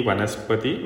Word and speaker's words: Vanaspati: Kanakangi, Ratnangi, Ganamurti, Vanaspati,